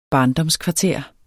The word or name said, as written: barndomskvarter